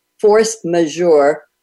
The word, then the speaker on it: force majeure: It's force majeure provisions. Did I say that right, Jim? – You did, right.